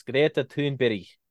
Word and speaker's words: Greta Thunberg: Oh, and by the way, for those of you who say that I'm not pronouncing that right, I am Americanizing it a little, but it in her home tongue, it's Greta Thunberg. That's how it's pronounced.